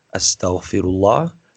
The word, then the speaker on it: Astaghfirullah: Astaghfirullah. It's five syllables, Astaghfirullah, Astaghfirullah, Astaghfirullah, Astaghfirullah.